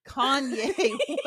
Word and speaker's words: Kanye: Cayenne West. Kanye West.